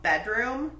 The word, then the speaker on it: BDRMM: The first album I want to talk about is a shoegaze band from the UK called Bedroom, but it's spelled BDRMM.